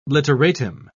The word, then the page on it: literatim: British English